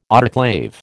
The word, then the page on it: autoclave: American English